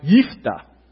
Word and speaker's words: Jephthah: It is really not Jephthah. It is Jephthah, just like J-E-F-F. And in Hebrew, it would be something like Yiftah.